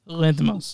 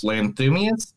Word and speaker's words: Lanthimos: Yorgos Lanthimos. – Lanthimos.